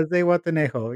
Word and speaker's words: Zihuatanejo: Is that the correct way to say it? – Yeah, Zihuatanejo, yeah.